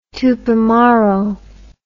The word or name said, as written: tupamaro